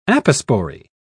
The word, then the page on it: apospory: British English